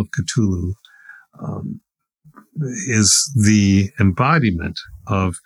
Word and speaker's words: Cthulhu: It's pronounced Cthulhu. Cthulhu.